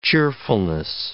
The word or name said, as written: cheerfulness